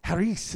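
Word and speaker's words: Charis (χάρις): Charis is how you say it in Greek, grace.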